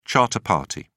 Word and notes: charter party: British English

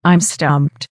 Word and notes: im stumped: American English

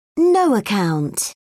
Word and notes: no-account: British English

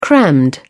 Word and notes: crammed: British English

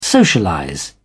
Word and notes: socialize: British English

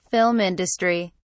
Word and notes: film industry: British English

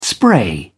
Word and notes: spray: British English

